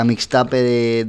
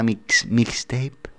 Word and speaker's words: mixtape: Pues eso, nos vamos con Plata y Negro desde Lone, de la misma mixtape. ¿Vale? ¿Está bien pronunciado? Mixtape. – Te doy un 8 raspado.